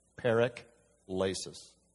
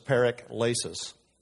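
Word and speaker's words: paraklesis: Because this is written in Greek originally, I want to talk about this word consolation, which is a word that comes from a Greek word called parakleisis, how that's pronounced, parakleisis. I know you've heard the word paraclete. It's similar. This is parakleisis.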